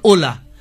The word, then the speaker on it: Hola: আপনি এই যে ক্যাবটা, ওলা, ওলা যে ক্যাবটা চলে, স্প্যানিশে এইচ দিয়ে লেখে, HOLA, উচ্চারণ ওলা, মানে হ্যালো।